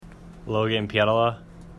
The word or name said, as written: Pietila